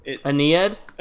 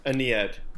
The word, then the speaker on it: Aeneid: It's definitely Greek, but it's like there's A-E-N-I-D. – Aeneid? – Aeneid, yeah. – That sounds right. Pronunciation wise and content wise.